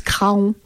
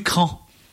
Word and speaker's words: Craon: Mais je ne suis pas sûre qu'on prononce Craon. – Ah, bah voilà. – Je ne l'ai pas dit, mais je l'aurais dit. – On dit Cran.